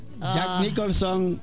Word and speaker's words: Jack Nicholson: Jack Nicholson is Frank Costello.